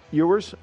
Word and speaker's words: Ewers: How do you say his last name? – Ewers.